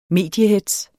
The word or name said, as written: mediehetz